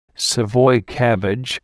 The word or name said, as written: savoy cabbage